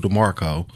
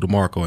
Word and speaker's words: De'Marchoe: It's called 22 DeMarco, and my name is spelled D-E-M-A-R-C-H-O-E, 22 DeMarco, and you can go watch this video.